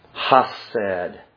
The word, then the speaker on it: hesed: H-E-S-E-D. Hased.